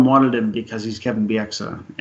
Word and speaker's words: Bieksa: I just want to point out, I did not know that Kevin Bieksa actually, Bieksa, let's try to say his name correctly.